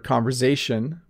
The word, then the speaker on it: conversation: I notice you pronounce the word conversation with a Z sound or Z sound if you're American.